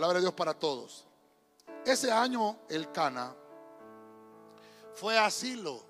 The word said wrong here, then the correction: Siló: con su familia asiló, tiene acento en la obra, fue asiló con su familia.